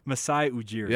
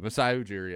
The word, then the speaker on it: Masai Ujiri: You shot Mujir. How do I always? I always mispronounce his name. – Masai Ujiri. – Yeah, that's it. Masai Ujiri.